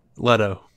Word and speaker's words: Leto: Is it Leto or Leto? – Leto.